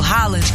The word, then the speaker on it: Haaland: Lembra que a gente falou sobre a pronúncia do nome do Haaland, que era Haaland? – Aham.